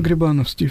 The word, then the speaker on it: Грибановский: Кстати, слышал ударение Грибановский, правильно Грибановский? – Правильно Грибановский, все. – Грибановский. – Да, что... – Хотя поголовно говорят Грибановский.